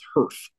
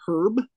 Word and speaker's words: Herff: Never have heard of Herf as a name for anybody, but that's what he went by mostly, was Herf. So I promise you, I am not mispronouncing Herb. It is Herf. H-E-R-F-F.